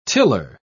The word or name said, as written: tiller